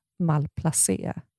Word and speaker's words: malplacé: Och där står det ju fonetiskt hur man ska uttala ordet. Och då fick jag reda på då att det uttalas malplacé.